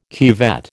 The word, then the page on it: cuvette: American English